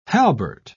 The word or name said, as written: halbert